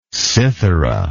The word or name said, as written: citharist